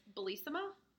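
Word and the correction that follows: Bellesa: Oh, no, it's Bellissa.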